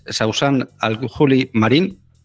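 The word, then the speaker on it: Sausan Al-Khouli Marín: Una de ellas es Sausan Al-Juli Marín, no sé si lo he pronunciado bien, Sausan, corrígeme. Militas en Masar Badil, que es el movimiento Ruta Revolucionaria Alternativa Palestina, y en el Al-Karama. O Al-Jarama, Movimiento de Mujeres Refugiadas Palestinas. Hola, ¿estás ahí? – Sí. Sí, hola, estoy aquí, y lo has pronunciado perfectamente.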